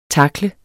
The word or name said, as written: takle